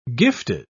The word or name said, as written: gifted